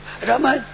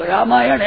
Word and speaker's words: रामायण: रमन, हाँ रमेन, रमियान, ये नाम लेते हैं रमेन और रमियान, ये नाम रामायण ऐसा नहीं उच्चारण मैंने अंग्रेजी पढ़ ले, रमियान और रमेन, वो ऐसी गवर्ण है।